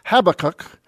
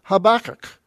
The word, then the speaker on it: Habakkuk: And the rule of thumb is that The accent, when it's double K, it's right between the two K's, and if it's double B, it's at the two B's. Some people pronounce it Habakkuk, and some people pronounce it Habakkuk, okay?